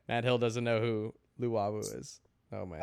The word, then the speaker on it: Luwawu: How are you pronouncing that name? – Lualu. Is that now how you pronounce it? – Luwawu. There's no L. – Oh, Luwawu.